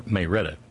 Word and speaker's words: Marietta: Joey says, at the Reds game tonight, on the way to Atlanta for a Warnock fundraiser tomorrow in Marietta. Or Mayretta, as it's pronounced down there.